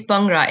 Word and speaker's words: Bhangra: It's Bhangra. It's actually like Punjabis will say it's almost like imagine it starts with the letter P. It's like Bhangra, Bhangra.